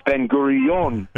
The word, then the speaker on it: Ben Gurion: Sometimes I tell people, it was shocking me to discover the name of the airport isn't Ben Gurion Airport; it's Ben Gurion. – Yes. – That's how Israelis pronounce it.